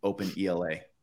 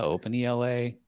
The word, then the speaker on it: OpenELA: And everyone's free to pronounce it however they want, but I pronounce it, I'm OpenELA. – Yeah. – Okay. – There's a lot of comments being come in. Ella, OpenELA.